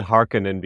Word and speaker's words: Harkonnen: So it's like, I pronounce it Harkonnen because it looks like a Finnish name.